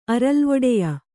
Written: ಅರಲ್ವೊಡೆಯ

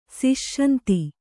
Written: ಸುಸಂಧಿ

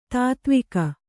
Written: ತೊತ್ತುವಗ

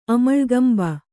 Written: ಅಮಳ್ಗಂಬ

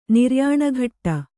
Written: ನಿರ್ಯಾಣಘಟ್ಟ

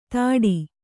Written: ಥಡಿ

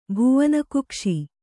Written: ಭುವನಕುಕ್ಷಿ

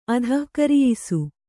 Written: ಅಧಃಕರಿಯಿಸು

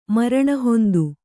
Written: ಮರಣಹೊಂದು

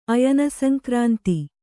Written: ಅಯನಸಂಕ್ರಾಂತಿ

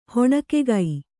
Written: ಹೊಣಕಿಗ

